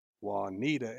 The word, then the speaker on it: Juanita: And it was on a clue, and we got this audience member to stand up and read the clue, and he pronounced it Juanita. And Olivia did this, like, it was like probably the best timing ever, this pause, and she goes, it's pronounced Juanita, and it brought the house down.